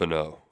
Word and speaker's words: Finau: Pronounced F- Finau.